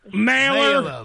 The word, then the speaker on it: Mahler: You can call me Mahler.